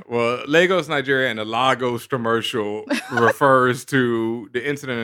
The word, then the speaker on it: Lagos: The Lagos paper towel. – Lagos. Don't pronounce it. Don't do that.